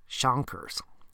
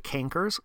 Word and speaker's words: chancre: The primary stage, this is open sores or lesions on the genitals called chancres. Now, some people say cankers, but don't confuse that with a canker sore, which is on your mouth. Technically, this is the right pronunciation, chancre.